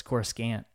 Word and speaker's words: Coruscant: Or you could say it like George Lucas does, Coruscant.